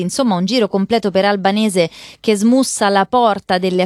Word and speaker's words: Albanese: Queste le parole del parlamentare Anthony Albanese, o Albanese, a pronuncia australiana, che ha ammesso di essersi sbagliato, che il sistema di respingimenti delle imbarcazioni, dei centri di detenzione, funziona.